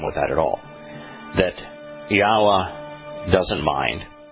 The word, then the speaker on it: Yahweh: There is no question that the Yod Heh Wah Heh is Yahweh's name, and that it is pronounced Yahweh. That there is no question. Now, is it possible that we are putting an emphasis on the wrong syllable? Is it possible that the vowel O is more of an oo sound than an O sound?